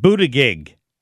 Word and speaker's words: Buttigieg: Pete Buttigieg.